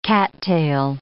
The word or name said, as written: cattail